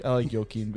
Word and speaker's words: Joaquin: Was it Joaquin? – It's Joaquin. – Yeah. – Yeah. – I'm almost certain. – Yeah. I like Joaquin better.